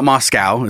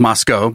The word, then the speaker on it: Moscow: It's not Moscow. It's just Moscow because, you know, America.